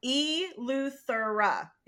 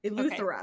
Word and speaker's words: Eleuthera: I just had to learn how to pronounce the name of the island. So it's Eleuthera. Eleuthera.